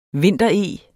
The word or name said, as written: vintereg